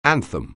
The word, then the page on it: anthem: anglais